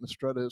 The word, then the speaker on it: Mistretta: Am I saying Mistretta case correctly? – You're saying, you're mispronouncing case slightly, but Mistretta is correct, yes.